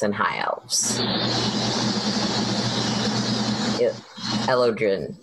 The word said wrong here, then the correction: Eladrin: Eladrin. – That word.